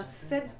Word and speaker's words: said: Hein, c'est un said comme si c'était. – Comme Cédric. – Comme Cédric ou comme Seb.